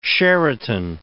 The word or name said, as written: Sheraton